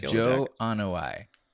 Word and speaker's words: Joe Anoa'i: Joe Anoa'i, I believe is how you say it. – Yes, Joe Anoa'i, the cousin of former Miami Hurricanes lineman Dwayne Johnson.